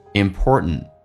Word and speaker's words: important: 我们来看一下，首先其中有一个实义词汇叫 important， T 跟 N 在一起的时候，或者 N 跟 T 在一起的时候，那么 T 都可以不发音，那这边通常口语化当中不会说成 important 会变成 important， t 不发音，第一个 t 最后一个 t 也不发音，